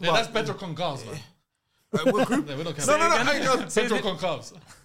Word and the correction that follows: Gonçalves: Isn't that con calves? – That's Gonçalves.